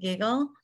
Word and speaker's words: GIGIL: And I keep wanting to pronounce it Giggle. But that is technically correct.